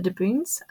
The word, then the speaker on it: De Bohun: And Thomas Woodstock was known to have married into a family called the De Boons. It's spelled De Bohun, but I think it's pronounced De Boon.